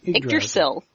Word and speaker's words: Yggdrasil: Might as well be Yggdrasil, or however you pronounce that. – Yggdrasil.